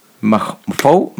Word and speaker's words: Mpho: M-P-H-O apostrophe Gifkin Opie. – Jesus. – Mafo Gifkin Opie.